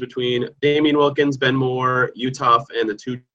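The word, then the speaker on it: Uthoff: It's Jared Utoff. You don't say the H in his last name. And his Jared is spelled J-A-R-R-O-D.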